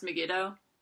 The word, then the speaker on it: Megiddo: Because the city is, I think, pronounced Megiddo.